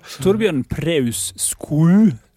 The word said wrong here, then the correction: Skau: Hvordan uttaler du det? – Ja, det er Torbjørn Preus Skau, så det er som ditt etternavn egentlig. – Bare med en O? – Bare med en O, ja.